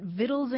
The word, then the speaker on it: victuals: And so they put the letter C back into it and started spelling it V-I-C-T-U-A-L. But the thing is that the old pronunciation was already so well established that people kept pronouncing it victuals anyway. So today you'll see either the word victual spelled V-I-T-T-L-E or V-I-T-T-L-E-S,